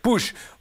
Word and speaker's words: Puszcz: Alors, c'est P-U-S-Z-C-Z. Comment vous le prononcez, vous? – Ça, c'est Pouche, oui. – Pouche.